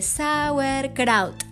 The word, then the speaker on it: Sauerkraut: Y resulta que es idénticamente Sauerkraut, tal como se escribe.